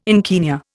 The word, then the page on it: encaenia: Indian